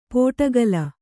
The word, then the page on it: ಪೋಟಗಲ: pōtagala